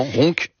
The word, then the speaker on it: Roncq: Le boulet à la bière, un fromage fabriqué à la ferme du Vinage à Roncq, je le prononce bien, c'est juste à côté de Tourcoing, c'est exactement, Roncq.